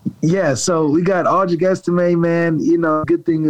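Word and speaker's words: Audric Estimé: Okay, and help me with the pronunciation. Is it Audric Estime? Is that how you say his last name? – Yeah, Audric Estime.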